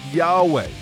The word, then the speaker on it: Yahweh: They took out the vowel so that all they had was YHWH. They pronounced it Yahweh.